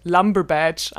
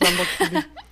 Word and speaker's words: Lamborghini: Ja, wo es noch einfach so mega blöd ausgesprochen wird. Lumberbadge anstatt Lamborghini.